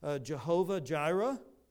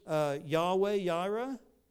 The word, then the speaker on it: Yahweh Yireh: And so probably you've heard the reference pronounced Jehovah Jireh, but it's really Yahweh Yireh.